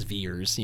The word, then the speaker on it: Vers: So they pronounce it as Veers, you know, or as you and I want to pronounce it, Vers.